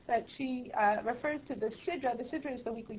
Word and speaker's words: sidra: It's a mispronunciation of the sidra. – Well, it's the sidra. – So, right, but sidra really is a sidra, not a sidra. But listen, either a mispronunciation or, let's say, a dialect.